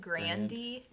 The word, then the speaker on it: Grande: Ariana Grande's last name is she pronounces it Grandy. – Yeah. – Grandy.